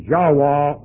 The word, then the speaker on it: Yahweh: But about as close as you can get to pronouncing the Hebrew is Yahweh or Yahveth.